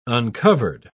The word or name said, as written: uncovered